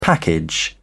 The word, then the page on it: package: British English